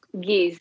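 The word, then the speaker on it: Ge'ez: So is Giz, that's how you say it, right? Giz, you said? – Giz, yeah.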